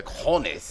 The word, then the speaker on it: cojones: And if the Cap was brave enough, if he had the cojones. – That's right. – And I think I said that right? – Yeah, you said it. Right, there you go. The cojones.